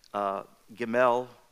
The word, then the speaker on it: Gimel: Incidentally, you probably remember from times past the number three, Gimel, that's how you pronounce it in the Hebrew, means the number three, but it also represents camels who are burden bearers and provision.